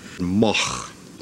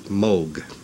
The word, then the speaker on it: Moog: Well, there are three ways of pronouncing M O O G Moch, Moog, Moog. I just happen to like the sound of Moog better than Moog.